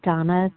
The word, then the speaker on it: Dana: This is Donna B., recovered compulsive overeater and bulimic, calling from Denver. My name is spelled D-A-N-A This paragraph reminds me of the phrase later on about strange mental blank spots.